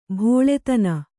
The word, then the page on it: ಬಲ್ಲತನ: ballatana